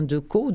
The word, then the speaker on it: Decaux: Un autre livre que je vous recommande, celui-ci est au SQLA, il est encore d'Alain Decaux. Decaux, ça s'écrit D-E-C-A-U-X.